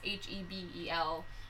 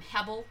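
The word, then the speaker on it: Hebel (הבל): And the way that it's used, because that word itself in Hebrew, I don't know how to pronounce it, but it's H E B E L, um, Hebel, mm-hmm. Hebel.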